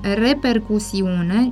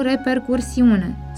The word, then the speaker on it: repercusiune: Este corect să spunem și să scriem repercusiune, nu repercursiune. Substantivul repercusiune, care înseamnă urmare, efect, consecință, a fost împrumutat în limba noastră cu această formă, deci cu silaba cu.